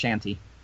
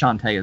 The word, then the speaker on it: Shantae: Yeah, though I didn't realize until I saw it in Japanese that her name is apparently pronounced Shanti.